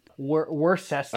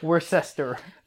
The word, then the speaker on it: Worcester: How do you supposed to say it? – Worcester. – I say Worcester. – The technical pronunciation.